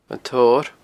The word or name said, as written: An Torr